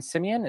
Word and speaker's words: Simien: However, Justin Simien, is that how you say his name? Mm-hmm.